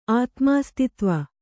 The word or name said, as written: ಆತ್ಮಾಸ್ತಿತ್ವ